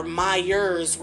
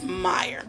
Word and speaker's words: Meyer: I called her Myers with an S, and she actually has no S on the end of her name. Her last name is Meyer.